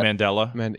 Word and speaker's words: Mandela: And it's like an audible, like, example of like a mandala effect going on. Am I saying that right? Probably not. – Mandela. – Yeah. – Mandela, that was, yeah.